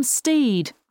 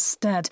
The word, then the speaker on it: Stead: Just interrupting the podcast here to clarify that we're talking about William Steed. I just mispronounced it as Stead.